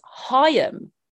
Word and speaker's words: Haim: It's actually pronounced Haim. What? The three girls, because it's their last name. So it's Haim. And it's like L'Haim, like Jewish, like, good luck or cheers or whatever.